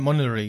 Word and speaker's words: Monterey: Ähm, ist übrigens aufgefallen bei, als äh, er macOS 12 vorgestellt hat, äh, da hat er erst macOS Monterey gesagt und äh, ne, zwei Sekunden später hat er Monterey gesagt. Also so hat er das so ausgesprochen. Das ist das T irgendwie hm. verschluckt.